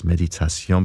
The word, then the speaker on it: méditation: Okay, here's this piece involving meditation, or should pronounce it in French, of course, méditation, because this is from French composer Jules Massenet, and it's one of his most famous pieces, if not the most famous.